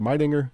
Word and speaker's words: Meidinger: Well, you write that not only is the Meidinger Tower, and am I saying that right, is it Meidinger? – Yeah, that's what I've heard, yes. I've heard a couple of different ways to pronounce it, but that's the way it sounds in my head, at least.